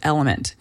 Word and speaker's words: LMNT: One of the ways that I love to do this is with Element. It's spelled L-M-N-T.